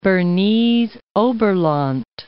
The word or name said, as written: Bernese Oberland